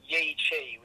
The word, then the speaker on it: Yi Qi: There's a dinosaur called Yi Chi, which is spelled Y-I-Q-I.